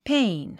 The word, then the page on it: pane: péin; ペイン